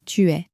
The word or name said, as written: tu es